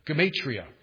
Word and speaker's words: gematria: 666, they use what's called in the Greek gematria, I think in the English proper pronunciation, gematria.